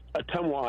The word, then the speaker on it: Ottumwa: It's pronounced Ottumwa, Iowa. Hey, Ottumwa.